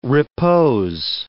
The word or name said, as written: repose